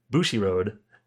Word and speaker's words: Bushiroad: And one thing that's sort of interesting about this is it's from Bushiroad, if I'm pronouncing that correctly. – Yeah.